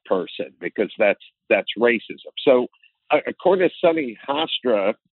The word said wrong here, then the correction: Hostin: or however her last Hostin. – Name is pronounced, Hostin,